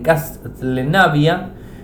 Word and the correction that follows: Castlevania: eh, no, eh, Castlevania, así lo dije bien, Castlevania, eh, Symphony of the Night, con lanzamiento sorpresa tanto en Android como en iOS.